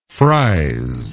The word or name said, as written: fries